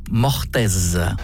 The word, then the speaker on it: mortaise: Ou c'est un mot que Jamel a du mal à prononcer? – Mortes. – Non, mortaise.